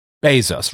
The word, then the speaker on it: Bezos: Jeff Bezos. Rhymes with Tezos, but it's worth slightly more.